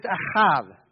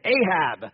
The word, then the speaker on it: ahav: It's ahav, ahav. And if you're just straight up English and don't know how to pronounce it, you would say Ahab.